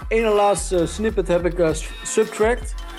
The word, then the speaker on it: SBTRKT: Zijn naam schrijf je als S-B-T-R-K-T. Maar spreek je uit de Subtract.